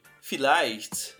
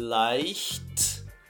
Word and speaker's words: vielleicht: Aí depois, o menino, o Junge, ele responde: vielleicht. Ó, a pronúncia é leicht, leicht. Depois viel, vielleicht 25.